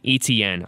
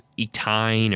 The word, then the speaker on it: Etienne: It's a hard name to pronounce, if you ask me. Etienne. I've had to really practice that. For the longest time, I thought it was Etine or Etwine.